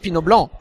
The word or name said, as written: Pinot Blanc